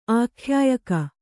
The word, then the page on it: ಆಖ್ಯಾಯಕ: ākhyāyaka